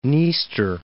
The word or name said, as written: Dniester